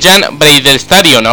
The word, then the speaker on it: Jan Breidel Stadion: Jan Breidel Stadion.